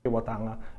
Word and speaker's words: Takiwātanga: Takiwātanga, which is the main purpose as well, why we are here in Hamilton, in Karakiri building, interviewing, of course, Keri Opai.